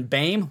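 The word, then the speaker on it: Boehm: They were new left guard Evan Baim, which I guess sounds like Bane. Didn't know that's how you pronounce that last name.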